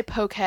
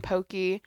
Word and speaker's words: poke: Okay, yeah, I've heard people say poke and pokey. – I always think it's pokey. – That's what I've heard my like whole life, so I think it's pokey, but.